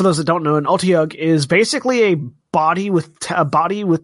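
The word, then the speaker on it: Otyugh: Standing at the bottom of the cistern, Tiberius and Kashal and Keyleth stare down a giant Otiug. How do you say it again? – You said it right.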